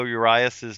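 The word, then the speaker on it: Urías: And am I pronouncing that right, Ben, Urias? – Yeah, Julio Urias.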